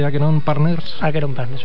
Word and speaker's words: Acheron Partners: Actualmente, como ya hemos dicho, eres el fundador y socio director de Alumni Global Search, pero también de, ¿cómo se dice exactamente? Acheron Partners, perfecto.